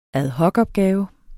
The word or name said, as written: AD